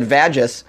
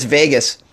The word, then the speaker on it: vagus: I said vagus, but it's Vegas.